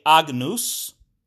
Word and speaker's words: Agnus: It's also commonly pronounced as nya. So I like to say Agnus, for that means lamb. I like to say Agnus, but you'll hear a lot of people saying Agnus.